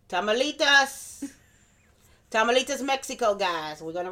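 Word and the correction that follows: Tamaulipas: Tamalipas. Okay. You're putting an extra T in there.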